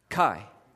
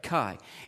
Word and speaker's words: chi: Chi, that's right, chi. It does have that hard K sound, chi.